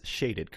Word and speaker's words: Shaded: It is Shaded, correct? – It is correct. Yes. Long A.